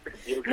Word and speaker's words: Piuquén: Piuquén, no sé. En realidad es como la castellanización de un vocablo mapuche que aparentemente se pronuncia Piuquén, que significa corazón.